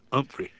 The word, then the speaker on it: Humphrey: Or Humphrey, as Johnson used to call him. Southern pronunciation, you don't pronounce the H. Humphrey.